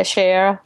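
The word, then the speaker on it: Shayera: Did I say her name right? – Yeah, Shaira. – Shaira.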